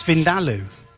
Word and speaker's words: vindaloo: it's not Vindaloo, it's Vindaloo. – Okay. – The emphasis is on the da.